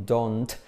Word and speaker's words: don't: Curioso con don't, que la T no se pronuncia, se dice como don, don Alberto, don José, don Antonio, don't look at me, don't look at me.